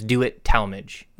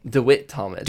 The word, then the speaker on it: DeWitt Talmage: Just know, I believe our official pronunciation is DeWitt Talmage, right? – DeWitt Talmage.